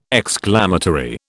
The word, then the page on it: exclamatory: British English